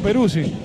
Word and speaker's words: Perrucci: Este Perrucci, habría que decirle, si le digo Palazzo a Palazzo, tendría que decirle Perrucci a Perrucci. – ¿Cómo pronuncia usted, eh? – Pero futbolísticamente lo conocemos como Perrucci. – Perrucci. Doble R y doble Z.